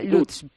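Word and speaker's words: Łódź: En este caso en una pequeña ciudad que se llama Lodz. Ahí vive César, es otro de esos españoles que le ha cogido, parece, el gustillo al frío. ¿Cómo? Lutz. – Se pronuncia Lutz.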